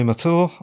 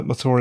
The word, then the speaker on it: Mathur: My name is Roy Mathur. I'm a writer. Mathur is spelt M A T H U R.